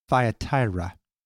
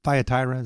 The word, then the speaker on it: Thyatira: And now we come up to a place called Thyatira. Is that how you pronounce it? Thyatira?